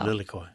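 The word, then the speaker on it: lilikoi: Lily koi.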